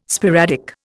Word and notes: sporadic: Indian

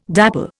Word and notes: dapple: Indian

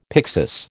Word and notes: pyxis: American English

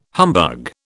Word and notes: humbug: British English

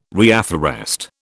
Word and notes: reafforest: British English

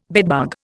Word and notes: bedbug: Indian

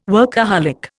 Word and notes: workaholic: Indian

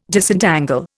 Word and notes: Disentangle: Indian

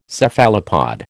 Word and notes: Cephalopod: American English

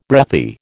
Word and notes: breathy: American English

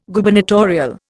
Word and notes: Gubernatorial: Indian

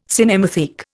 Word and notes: cinematheque: Indian

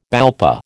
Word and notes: BALPA: American English

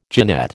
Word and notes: genette: American English

